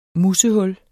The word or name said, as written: Musehul